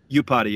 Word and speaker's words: Iupati: Is it Iupati? – Iupati, yeah. – Iupati, there you go.